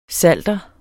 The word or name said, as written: PSALTERENS